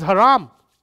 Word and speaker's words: haram: It was haram. Haram, I don't want you to think I mispronounced haram.